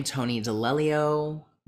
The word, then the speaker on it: Tony D'Alelio: At my name, Tony D'Alelio.